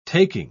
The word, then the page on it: taking: British English